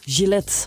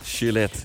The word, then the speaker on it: Gillette: Gillette. Gillette, ja.